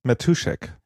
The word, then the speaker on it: Matuschak: Matuschak. Also richtig würde es wahrscheinlich Matuschak ausgesprochen, so tschechische Matuschak.